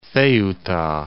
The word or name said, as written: Ceuta